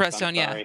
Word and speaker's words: Mifepristone: Mifepristone, yeah.